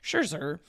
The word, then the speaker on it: Scherzer: They are losing Max Scherzer, which is great because I always can't say his name. It makes me sound like I just have a very... elaborate lisp. Scherzer.